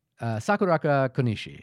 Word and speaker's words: Sakurako Konishi: Yeah, played by, I want to see if I get this one right, Sakurako Konishi. – Sakurako Konishi. Yeah, I think that's right.